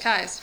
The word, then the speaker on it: Keyes: It's written like Keyes. It's pronounced Kais.